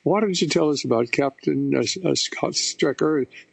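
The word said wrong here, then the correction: Speicher: Is that the way to pronounce his name? – Spiker.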